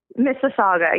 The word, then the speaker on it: Mississauga: She's a postdoctoral fellow at the University of Toronto, Mississauga. Did I pronounce that correctly? – Mississauga. Yeah, that's right.